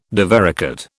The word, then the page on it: divaricate: British English